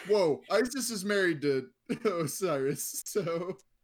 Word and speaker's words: Osiris: We call him Osiris now, but apparently the pronunciation correctly back in ancient Egypt, according to some historians, is Woe-seer, which is really fucking weird.